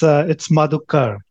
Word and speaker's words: Madhukar: Yeah, it's Madhukar, but I started off as a developer.